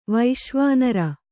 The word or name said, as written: ವೈಶ್ವಾನರ